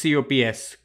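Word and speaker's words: Copse: So I think there was this one corner that everybody was keeping an eye on called the Cox corner. Did I say it right? – It's COPS, Cops.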